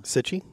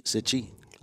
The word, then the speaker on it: Cichy: Cichy. – Cichy. Yeah, Itchy. Cichy. – Rhymes with itchy? – Yeah.